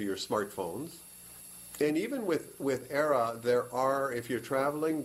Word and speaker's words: Aira: ERA. It's spelled A-I-R-A. So it's the word air with an A on the back.